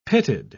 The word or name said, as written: pitted